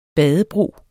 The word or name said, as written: badebro